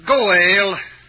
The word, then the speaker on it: goel: The Hebrew word translated redeemer is goel. Sometimes it's spelled G-A-A-L, English equivalent. Most of the time, G-O-E-L. Goel.